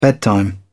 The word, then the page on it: bedtime: British English